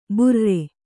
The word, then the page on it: ಬೇರೂರು: bērūru